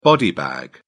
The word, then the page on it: body bag: British English